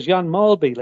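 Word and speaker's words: Jan Mølby: Jan Mulby. Like everybody in the English-speaking world called him Jan Mulby. But he was right. George was right. That is the Danish pronunciation.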